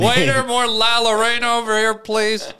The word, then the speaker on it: La Llorona: No, La Llorona, I think you're saying. – Yeah, is that how you say it? See? – Llorona, because it's two L's.